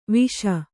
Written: ವಿಷ

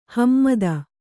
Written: ಹಿಮ್ಮತು

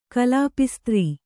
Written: ಕಲಾಪಿಸ್ತ್ರೀ

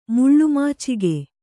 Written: ಮುಳ್ಳುಮಾಚಿಗೆ

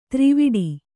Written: ಥರವಿಡಿ

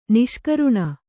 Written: ನಿಷ್ಕರುಣ

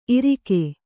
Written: ಇರಿಕೆ

